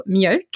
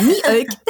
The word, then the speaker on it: Mjölk: Und ich äh, mache mich mal darüber so ein bisschen lustig, sage ich mal in Anführungszeichen, weil ich das mit Absicht eigentlich immer falsch ausspreche. Ich sage mal Mjölk.